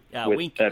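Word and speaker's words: Winke: His name is Bill Winky, or is that how you pronounce his name? – Winky. – Midwest Whitetail. – Yep, Winky.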